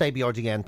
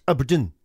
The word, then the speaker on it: abrdn: A-B-R-D-N. That's the name. But not pronounced A-B-R-D-N. Pronounced Aberdeen.